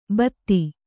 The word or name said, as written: ಭಾತಿ